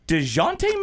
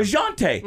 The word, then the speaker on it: Dejounte: Is it DeJounte Murray? DeJounte Murray? – You got it right the first time. – DeJounte.